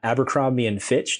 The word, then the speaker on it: Abercrombie and Fitch: But I remember watching a YouTube documentary about the store Abercrombie and Fitch.